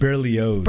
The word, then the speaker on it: Berlioz: Yes, that was, how do you pronounce it? Berlioz? – Berlioz. – Berlioz. – Yeah.